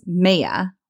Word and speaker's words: Maia: Not Maia. The Roman goddess Maia, which is spelled M-A-I-A.